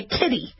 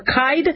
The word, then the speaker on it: Kidde: Kidde. Or do they pronounce it kid or kide? It's K-I-D-D-E. I look at it as kitty. Kidde, okay? Like, where are the kitties type of thing?